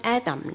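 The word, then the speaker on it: Adam (אדם): 而且呢亚当这个词呢，如果按照希伯来文的读音 Adam 而来呢，这个词呢除了代表那个始祖亚当之外，它都可以呢翻译为呢人这样解。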